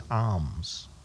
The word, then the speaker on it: alms: He's talking about the giving of, I use the word alms. I've been told that I pronounce it incorrectly. Apparently it's alms.